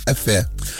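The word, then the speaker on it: Өфө: Правильно говорит? Эфе. – Это вот. Среди кого так правильно говорят? – А это среди уфимцев.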